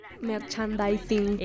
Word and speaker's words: merchandising: El merchandising, si es que se pronuncia así. – Merchandising.